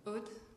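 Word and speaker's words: Aude: It's Ud, but it's a French name, so you couldn't pronounce it anyway.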